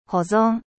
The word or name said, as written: conservation